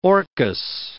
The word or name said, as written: orchis